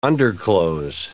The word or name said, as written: underclothes